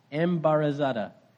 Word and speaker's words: embarazada: Embarazada. Sounds like embarrassed, but it doesn't mean embarrassed.